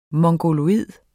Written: mongoloid

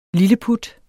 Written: lilleput

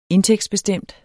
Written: indtægtsbestemt

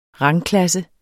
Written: rangklasse

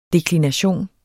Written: deklination

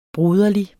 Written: broderlig

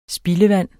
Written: spildevand

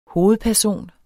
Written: hovedperson

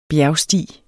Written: bjergsti

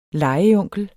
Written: legeonkel

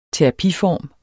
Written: terapiform

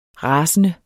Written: rasende

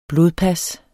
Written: blodpas

